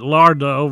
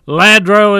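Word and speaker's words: Lladró: For years I called it Lardo, but Ladro is the pronunciation.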